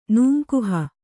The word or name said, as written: ನೂಂಕುಹ